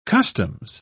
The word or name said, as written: customs